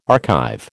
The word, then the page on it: archive: American English